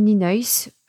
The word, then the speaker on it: Nienhuis: Et on trouve aujourd'hui en France assez facilement le matériel Ninois. Alors Ninois, je vous l'épelle parce que ça ne se prononce pas du tout comme ça s'écrit. N-I-E-N-H-U-I-S.